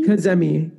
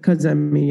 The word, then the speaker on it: Kazemi: Kazemi. Kazemi, yeah.